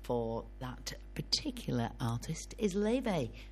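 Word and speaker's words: Laufey: I've been pronouncing it Lawfey because that's how you read it, you know, that's how it's spelt, Lawfey. But actually, it's an Icelandic name and it's pronounced Leve.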